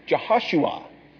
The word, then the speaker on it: Jehoshua: If you were to look at it in the Hebrew Bible, you would discover that it is Jehoshua. Jehoshua, really, is really the way it is pronounced, Jehoshua.